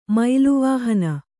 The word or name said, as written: ಮೈಲುವಾಹನ